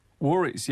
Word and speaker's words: Warries: Am I saying that right? Is that the name of the new album, Warries? – Warries, yeah.